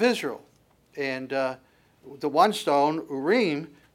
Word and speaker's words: Urim: so the word that he uses, Urim, would be the way that you pronounce it, Ur is the singular, Urim is the plural.